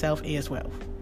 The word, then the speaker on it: Self X Wealth: It is spelled Self X Wealth, but pronounced Self Is Wealth.